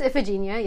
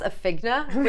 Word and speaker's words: Iphigenia: I think it's just Iphigenia, yeah. – Iphigenia? Okay. In my head, I pronounce Iphigna, which makes no sense.